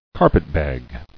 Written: Carpetbag

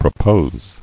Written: Proposing

